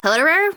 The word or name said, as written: Hoederer